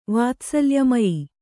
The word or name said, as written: ವಾತ್ಸಲ್ಯಮಯ